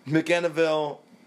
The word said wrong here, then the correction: McMinnville: all that McMinnville. – Shit. McMinnville.